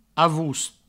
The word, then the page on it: avost: avoust; Provençal